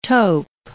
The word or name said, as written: taupe